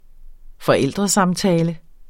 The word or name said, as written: forældresamtale